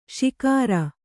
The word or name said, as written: ಶಿಕಾರಾ